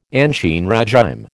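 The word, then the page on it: ancien régime: American English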